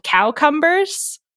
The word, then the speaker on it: cowcumber: But for a brief period of time in Middle English, they were called cowcumbers, and the pronunciation cowcumber was standard instead of cucumber because you didn't want them.